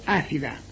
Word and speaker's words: ácida: Ácido, ácida. Axida. Se escribe ácida y la C se lee TS, luego ácida. Fácil.